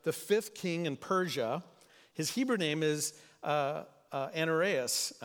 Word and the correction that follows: Ahasuerus: I didn't pronounce that correct. Hazareus, thank you.